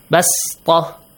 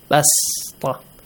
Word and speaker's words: بسطة: بسطة. – Yes. – Pronounce it like a sin. So it says Sad is pronounced the same as sin here. Pronounce the sound of Sad like a sin. Basta.